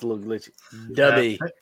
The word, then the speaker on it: Dubby: Dubby. D-U-B-B. It's right in the middle of our screen right there. Yeah. I put the logo on it. – Like hubby, but with a D.